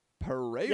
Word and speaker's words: Pereyra: Like literally P-E-R-E-Y-R-A. Pereira. – Yeah, that's how you say it.